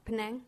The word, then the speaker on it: Penang: Penang? – Penang.